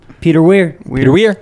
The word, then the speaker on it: Peter Weir: Peter Weir.